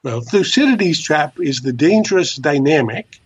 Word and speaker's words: Thucydides: So there's no single pronunciation, but in English, we call it Thucydides trap. – Thucydides.